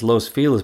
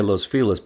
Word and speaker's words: Los Feliz: As an aside, Angelenos have referred to the neighborhood as Los Feliz for decades. In recent years, there's been a push to revert to the proper Spanish pronunciation of Los Feliz, but it's met with limited success. I'm going to say Los Feliz because I'm old school.